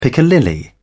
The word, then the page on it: piccalilli: British English